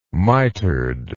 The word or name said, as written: mitered